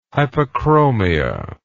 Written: hypochromia

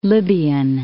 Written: Libyan Desert

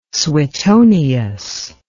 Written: suetonius

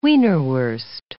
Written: wienerwurst

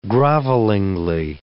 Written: grovelingly

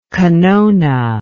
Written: kanone

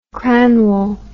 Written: cranwell

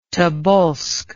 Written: Tobolsk

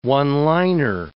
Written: one-liner

